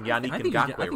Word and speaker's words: Yannick Ngakoue: I don't even know if I'm pronouncing Yannick Ngakoue right, but it's fun. – It, honestly, I think that's actually how you pronounce it. I'm not even kidding. Like, that's pretty good.